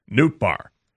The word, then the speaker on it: Nootbaar: And then I realized the spelling of his last name, Nootbaar, it's N-O-O-T-B-A-A-R, Nootbaar. And I thought to myself, how many people have four vowels in their last name and only two syllables?